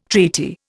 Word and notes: treaty: Indian

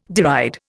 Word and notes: deride: Indian

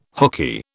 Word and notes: hookey: British English

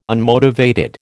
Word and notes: unmotivated: American English

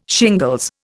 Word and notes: shingles: Indian

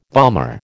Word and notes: bomber: American English

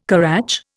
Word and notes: currach: Indian

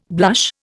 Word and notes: blush: Indian

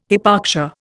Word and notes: hypoxia: Indian

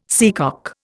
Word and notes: seacock: Indian

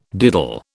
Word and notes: diddle: British English

